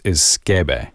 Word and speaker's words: sukebei: Because the pronunciation of the Japanese word is skebe. It's not spelled that way. It's spelled S-U-K-E-B-E-I, but I looked up several pronunciations.